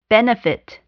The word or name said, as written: benefit